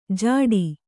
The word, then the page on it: ಝಟ್ಟಿ: jhaṭṭi